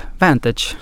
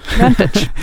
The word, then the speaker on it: Vantage: Det engelska uttalet ska vara Vantage. – Vantage.